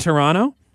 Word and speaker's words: Toronto: Oh, like Toronto, where we don't pronounce the T in Toronto? – Exactly.